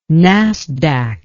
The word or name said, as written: Nasdaq